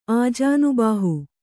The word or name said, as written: ಆಜಾನುಬಾಹು